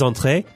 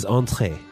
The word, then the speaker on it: entrez: It sounds the same, but I don't know. I'm thinking of the spelling in my head. The verb is entrez, and the command is entrez. – Yeah, exactly the same pronunciation.